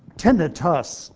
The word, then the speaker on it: tinnitus: So tinnitus, and I emphasize tinnitus, U.S. at the end, is the preferred pronunciation. Itis, I-T-I-S, is the common ending in medicine for an inflammation. This is not an inflammation.